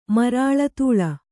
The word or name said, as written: ಮರಾಳತೂಳ